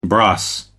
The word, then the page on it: bros: American English